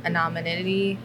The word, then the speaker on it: anonymity: so it's kind of like that anonymity. – Sure. – Did I say that right? – Anonymity. – Anonymity.